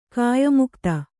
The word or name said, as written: ಕಾಯಮುಕ್ತ